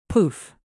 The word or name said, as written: poof